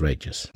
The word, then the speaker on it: Regis: You know, I had some professors and people in college that used to call me Regis, and I didn't care. It's like, okay, whatever. The people that were close to me, they knew my pronunciation was Regis.